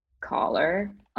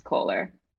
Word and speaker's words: collar: And so I actually just had to look up how to pronounce collar, which I thought was Kohler. This whole time.